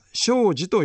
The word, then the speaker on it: 生死: 生まれてくる命と死んでいく命、生き死に、生死と書いて仏教では生死と読みますが、その生死のあるがままの姿を私はしかと見つめることになったのです。